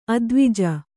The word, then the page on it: ಅದ್ವಿಜ: advija